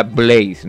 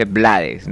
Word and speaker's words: Blades: Porque dijo el chombo que se decía Blaze, no se dice Blades, no se dice Rubén Blades, porque entonces lo dijo el chombo.